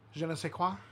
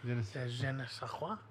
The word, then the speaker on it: je ne sais quoi: Like, the Genevieve Soir. How'd you say that word? – Je ne sais quoi. – Je ne sais quoi.